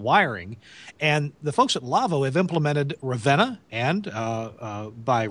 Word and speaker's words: LAWO: Our show is brought to you in part by the folks at LAVO, L-A-W-O, German company that makes audio consoles, pronounced LAVO.